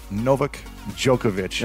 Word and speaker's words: Novak Djokovic: We have the legendary Novak Djokovic.